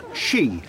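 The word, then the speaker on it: skíð: It used to be pronounced she and meant piece of wood.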